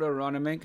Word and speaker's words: Aronimink: Is it called Aronimink? Is that how you pronounce it? – It's called Aronimink, and it's in Pennsylvania.